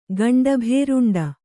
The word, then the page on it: ಗಂಡಭೇರುಂಡ: gaṇḍa bhēruṇḍa